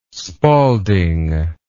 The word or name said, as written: Spalding